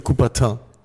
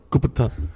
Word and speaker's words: Coubertin: Aber heißt das jetzt Coubertin oder Coubertin? Wie spricht man den aus? Du weißt das doch bestimmt. Ich würde sagen Pierre de Coubertin. – Coubertin. – Gut. – Auf jeden Fall.